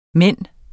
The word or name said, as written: mand